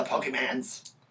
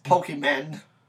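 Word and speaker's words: Pokémon: The Pokemans. With a Z at the end. – That was when parents would just be like, oh, the Pokemans. Like, Pokemon. – It's like, you're not even saying it right.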